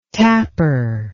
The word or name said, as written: tapper